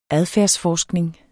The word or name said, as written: adfærdsforskning